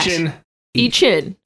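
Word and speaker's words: Ishin: How do we even pronounce that? – Ichin. – Yes.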